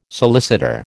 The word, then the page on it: solicitor: American English